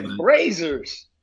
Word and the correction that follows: Brazzers: I think it's pronounced Brazzers.